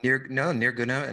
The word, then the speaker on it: Nirguna: But in the context of that framework, you know, Hinduism talks about, and you can help me with the pronunciation, Nirguna Brahman and Saguna Brahman, right? – Yes, that's right. – Can I say that better than I did? – Nirguna and Saguna is good. Yeah.